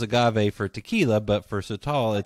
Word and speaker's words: Sotol: And we'll be talking about a different Mexican spirit called Sotol. Did I say that right? – Sotol, yes.